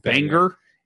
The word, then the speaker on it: Bangor: When I went to school in New England, there was a guy from Banger. – Banger. – Like the song's a banger.